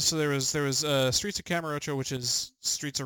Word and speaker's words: Kamurocho: Oh, it's Streets of Kamurocho.